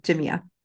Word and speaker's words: Jamea: And let me make sure I say your last name correctly. – Jamea.